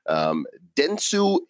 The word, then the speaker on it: Dentsu: Dentsu and Kadokawa.